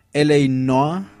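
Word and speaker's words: L.A. Noire: Muchas gracias a Josuke M, que me dice que es L.A. Noa. Mal, de todos modos lo pronuncié mal, no importa, ustedes me entendieron, ya saben de qué juego es.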